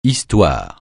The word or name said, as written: histoire